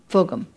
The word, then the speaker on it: Fulghum: But I'm tempted to read from Robert Fulghum. Fulghum. Say it right.